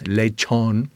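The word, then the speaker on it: lechón: Popular dishes include lechon, that's a roasted pork, pronounced lechon, rice and beans, mofongo, which is mashed fried plantains, and fried fritters, such as empanadas and alcapurrias.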